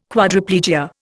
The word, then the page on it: Quadriplegia: Indian